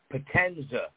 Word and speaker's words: Potenza: Now, let me tell all your viewing audience, it's a pleasure to be on your show, but you say my name, Potenza. Like this thing about, say, Pacino, Gandolfini, De Niro, Pesci, Potenza.